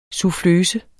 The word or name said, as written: suffløse